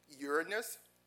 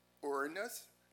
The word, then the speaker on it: Uranus: Another form of the word is Uranus, and this is where we get the word, and this word is actually can be pronounced a couple of different ways. Uranus, Uranus, Uranus, however you may want to pronounce it.